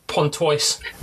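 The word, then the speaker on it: Pontoise: He has came from working in France National Junior Program, as well as taking the second-tier team to the how do you say that? John Line, Sergei Pontois. – There you go.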